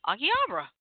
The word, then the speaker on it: Akihabara: Akihabara. – Okay. Akihabara.